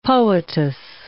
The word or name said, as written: poetess